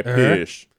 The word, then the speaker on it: Piëch: I'm just going to say Piëch. – The umlaut, you say the, this is real, you say the vowel sound with like a slight like. – Okay, Piëch.